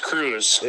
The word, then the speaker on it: Kruse: It is Kruse. Just cruising yep, along. – I met when we were racing over at Deer Creek. I actually met some Kruses there, spelled the same way, but they enunciate the E.